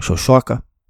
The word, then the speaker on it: Șoșoacă: Same thing goes for Diana Șoșoacă, which is actually how you say her name, which I had no idea.